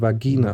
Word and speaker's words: Vagina: Der Duden erlaubt beide Aussprachen. – Du hast also die freie Wahl. Vagina sagt die äh, Maschinenstimme. Vagina. – Die original lateinische Aussprache, ist die Betonung auf der zweiten Silbe, Vagina.